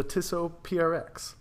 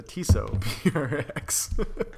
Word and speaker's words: Tissot PRX: And it is the Tissot PRX. I'm going to redo that because I butchered that. The Tissot PRX.